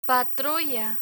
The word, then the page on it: patrulla: Spanish